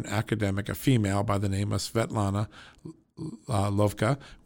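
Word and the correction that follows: Lokhova: We've had her on this show several times, and I'm sorry, Svetlana Lakova. Let me say that right. Svetlana Lakova.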